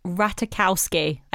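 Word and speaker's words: Ratajkowski: Emily Ratta Jakowski. – Jokowski. – I think it's Ratakowski. I think the J is silent.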